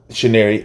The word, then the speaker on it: Chinyere: Chinyere.